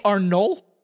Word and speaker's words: Arnolt: Uh, as was widely expected, Charly Arnolt, I believe the T is silent, better known as Charly Caruso, has signed a full time deal with ESPN starting this week.